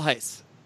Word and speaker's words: Heise: And you're still pronouncing his name wrong after all this. – How do you say it? – It's Michael Heise. – Heise. Oh. Yes.